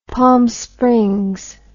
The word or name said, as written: palm springs